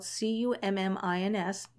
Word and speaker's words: Cummins: My last name is spelled C U M M I N S, no G, C U M M I N S.